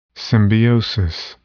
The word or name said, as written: symbiosis